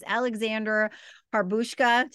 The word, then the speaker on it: Alexandra Harbushka: So our special guest today is Alexandra Harbushka.